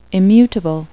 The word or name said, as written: immutable